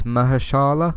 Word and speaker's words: Mahershala: Mahershala, there's a Shala in the name, so it's Mahershala, I think, Ali.